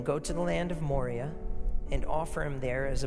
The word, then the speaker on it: Moriah: The Mount Moriah is how you pronounce it. Mount Moriah is how it often gets pronounced.